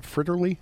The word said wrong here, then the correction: fritillary: Fritillary. – Fritillary, okay.